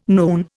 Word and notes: noon: Indian

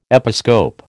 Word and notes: episcope: American English

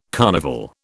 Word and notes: carnival: British English

